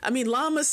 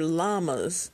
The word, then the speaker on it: llamas: Because I took Spanish, right? And so when I see the two L's together in Spanish, they pronounce it like a Y. So whenever I see that, that just comes to my mind. So I call it llamas, but they're llamas.